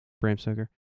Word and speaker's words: Bram Stoker: All right, so Basic Instinct or Bram Stoker's Dracula? Did I say that right? – Yep. – Bram Stoker.